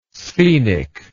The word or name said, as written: sphenic